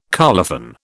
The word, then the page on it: colophon: British English